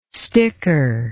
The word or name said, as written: sticker